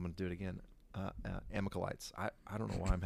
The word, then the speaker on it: Amalekites: Am I saying that right? Amalekites. – As long as you say it quickly, there it with is. Confidence, man. No one, that's right. Amalekites.